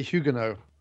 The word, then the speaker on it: Huguenot: It's actually Huguenot. – Is it Huguenot? – Yeah, you don't pronounce the T. It's a French word.